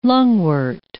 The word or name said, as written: lungwort